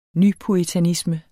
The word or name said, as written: nypuritanisme